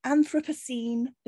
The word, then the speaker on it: Anthropocene: What an Anthropocene, is that how you say it? – That is how you say it.